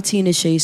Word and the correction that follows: Tinashe: Tinashe songs.